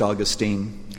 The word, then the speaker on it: Augustine: I conclude with the story of Augustine, sometimes pronounced Augustine, who was himself a rebellious child.